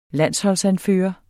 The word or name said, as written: landsholdsanfører